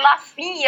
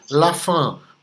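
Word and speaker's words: LaFin: Não, e quando eu apresentei a primeira vez, eu disse assim: eu queria só destacar para vocês que a pronúncia é francesa, lafan, porque é, o fim em francês é lafan. Lafan é isso mesmo.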